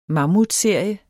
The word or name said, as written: mammutserie